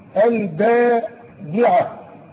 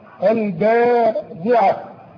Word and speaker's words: الباضعة: انا عاوزك تتعلم النطق. الباضعة. انظر كيف تنطق الباء مع الضاد. الباضعة.